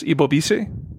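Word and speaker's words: Ebobisse: The kid from Duke, Jeremy, forgive me if I butcher this, Ibobisi? – Yes. – Yeah, I did it right.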